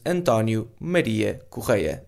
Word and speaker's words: Antonio Maria Correia: I probably shouldn't pronounce my name quite as fast, because not all people are Portuguese, so my name is Antonio Maria Correia. That means Antonio, or Antonio, Maria, Correia. Correia? I don't know.